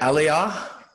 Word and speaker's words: Aliyah: You're actually from the UK, uh, but you're part of what the Bible prophesied as the uh, Aliyah. Am I saying that right? Aliyah? – Yep, that's pretty good.